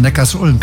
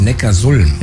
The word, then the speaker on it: Neckarsulm: Habe ich das richtig ausgesprochen? – Nee, ich glaube, das heißt Neckarsulm. – Ja, und warum? – Ja, weil es in der Datenbank steht. – Nee. Die heißt deshalb Neckarsulm, weil Ulm liegt an der Donau.